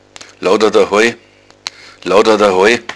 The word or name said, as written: laoudadahoi